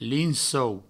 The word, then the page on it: lincou: provençal